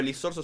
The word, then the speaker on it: Lizorzo: Me llamo Iván, sí, pero Lizorzo es una mezcla de mi segundo nombre y mi apellido. – Entonces, si se dice muchas veces lisorzo se pronuncia Sorsoli, que es mi apellido real.